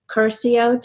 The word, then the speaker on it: Curcio: Just for my listeners, this is Sarah Curcio.